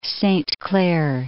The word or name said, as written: Saint Clair, Lake